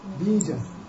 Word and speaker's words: bija: Long I. Bija.